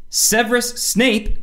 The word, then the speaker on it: Severus Snape: In the Harry Potter movies, Severus Snape.